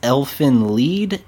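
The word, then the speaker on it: Elfen Lied: Uh, I think if uh, from what I've read, it's supposed to be pronounced Elfin Lied, and it's because it's a German I've...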